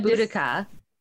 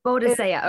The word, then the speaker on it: Boudica: I say Boudica. – The Boadicea Club is Boadicea. Okay. – Yeah. – But there are several different pronunciations. So like, in different times, eras in history, they've called it Boudica, Boadicea.